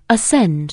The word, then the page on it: ascend: anglais